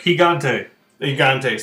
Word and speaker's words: Gigante: And then when we heard about El Gigante, and it's like... – Gigante, sorry. – Silent G.